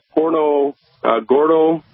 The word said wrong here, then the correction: Punta Gorda: Florida. – Punta Gorda, I believe it might be.